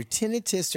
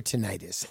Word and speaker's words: tinnitus: Everyone pronounces tinnitus as either tinnitus or tinnitus. And the Brits have one way. The Americans have another way. And I pronounce it both ways. So that question is easy to answer.